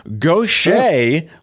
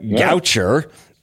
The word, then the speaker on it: Gaucher: I want to point out that you have a disease that I consider the Stephen Colbert disease because it looks different than it's pronounced and it's quite French. Gaucher looks like Goucher.